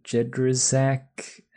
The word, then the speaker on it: Jedraszczak: I'm Alex the Jedi Jedrazak, and my name rhymes with Affleck.